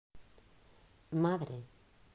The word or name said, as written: Madre